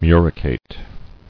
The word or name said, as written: Muricate